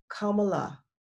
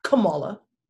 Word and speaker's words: Kamala: It's like the punctuation mark, the comma. Kamala, that's her name, Kamala. Not Kamala, and all the other type of racist variations that you're trying to associate with her name.